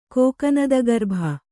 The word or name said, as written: ಕೋಕನದಗರ್ಭ